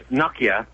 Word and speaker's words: Nokia: Oh, it's not Nokia. – It's Nokia.